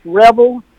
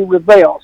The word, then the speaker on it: Revel: The British folks and English folks firmly say it's pronounced Revel, and a lot of the French people with French backgrounds say that it's absolutely Revel. So I answer to it either way. – How do you say it? – I most often say Revel, but sometimes if I'm in a certain environment, I say Revel.